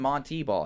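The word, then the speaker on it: Montee Ball: They called him Monte Ball his entire college career. And then he revealed it was actually pronounced Monte Ball.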